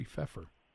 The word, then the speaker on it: Pfeffer: by Jeffrey Pfeffer. Am I saying that right? – Yes.